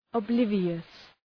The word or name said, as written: oblivious